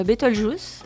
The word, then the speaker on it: Beetlejuice: Oui, avec des séances vendredi, samedi, dimanche, avec le film Beetlejuice, l'ai-je bien prononcé, je pense.